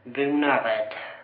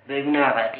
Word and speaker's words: Bonaröd: Bonaröd. Bonaröd, ja. De har, ja det är väl över hela Skåne, det som skrivs röd, det uttals red.